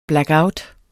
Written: blackout

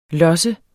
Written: losse

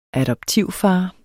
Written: adoptivfar

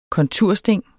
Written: kontursting

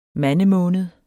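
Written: MANDEMÅNED

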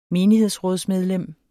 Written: menighedsrådsmedlem

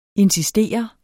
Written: INSISTERENDE

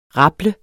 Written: rable

